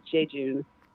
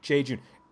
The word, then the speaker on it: Jejune: Now, I have never heard this word pronounced, but I think it's Jejun. Is that right? – That's how the people who never saw us and never heard us play or never heard us say the name ourselves always pronounced it. We always just said Jejun.